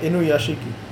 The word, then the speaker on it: Inuyashiki: Inuyashiki. – Inuyashiki.